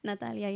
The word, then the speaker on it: Natalia: You said my name wrong, G. It's not Natalie. – What is it? Natalia? – Yes. – But I said it in an English accent. – You said Natalie. That would have E at the end.